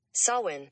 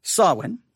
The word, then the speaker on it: Samhain: Samhain. – Samhain. All right. Samhain. Now, again, it's spelled S-A-M-H-A-I-N, Samhain.